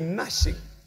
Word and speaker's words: gnashing: For me, I like calling it gnashing because the G is very important. Gnashing of teeth. I don't know why they don't pronounce the G.